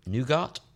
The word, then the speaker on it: nougat: Nougat? Nougat? – Nougat. Nougat. – You don't pronounce the T.